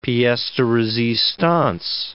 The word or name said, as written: pieces de resistance